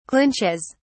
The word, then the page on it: clinches: British English